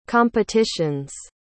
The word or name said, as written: competitions